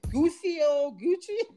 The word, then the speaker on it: Guccio Gucci: I think it's Guccio Gucci.